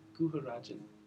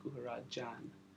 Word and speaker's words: Guharajan: Guharajan. Instead of J-E-N, the way it's pronounced is J-A-N. Guharajan.